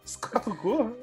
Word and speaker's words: escargot: Escargot? – É escargot.